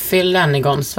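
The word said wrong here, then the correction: Penhaligon's: vad heter de? Penhaligons. Penhaligons. Ja, det var nära uttal.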